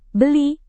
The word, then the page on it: beli: indonesian